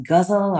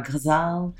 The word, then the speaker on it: ghazal: This poem is in a form called the, I hear it pronounced ghazal or ghazal, mm-hmm.